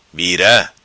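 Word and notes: Vira: patua

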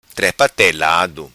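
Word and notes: Trepa Telado: Macanese Patuá

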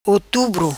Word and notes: Otubro: patua

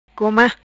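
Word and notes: Goma: patua

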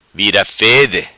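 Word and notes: Vida Fede: patua

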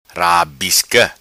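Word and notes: Rabisca: patua

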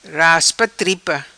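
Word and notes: Raspa Tripa: patua

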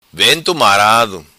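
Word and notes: Vento Marado: patua

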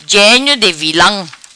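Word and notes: Genio Di Vilam: patua

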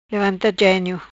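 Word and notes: Genio Levanta: patua

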